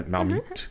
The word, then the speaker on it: Marmite: It's actually named after the container it once came in, which is a Marmite, I guess you might say in French. – Yeah, I couldn't tell by your earlier pronunciation. – Marmite. Yeah. – Ouch. – Sorry. Well, doesn't speak French. It's okay. Don't feel bad. – Yeah, Marmite. – That was much better.